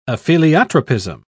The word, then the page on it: apheliotropism: British English